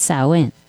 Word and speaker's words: Samhain: For my purpose, I'm going with the ancient Celtic festival of Samhain, actually pronounced Samhain.